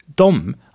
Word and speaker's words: de: De wird im Schwedischen sehr, sehr, sehr, fast 99% als dom ausgesprochen. In der Objektform, also sie oder ihnen, dann wird es dem geschrieben, aber auch noch dom gesprochen.